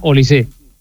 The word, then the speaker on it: Olise: No lleva acento, pero se pronuncia Olise.